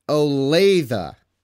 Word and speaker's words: Olathe: First off, it's Olathe. So we finally got the right pronunciation on that. Everybody from, is it Kansas? – Kansas.